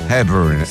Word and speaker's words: Hepburn: Hepburn.